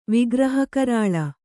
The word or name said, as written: ವಿಗ್ರಹಕರಾಳ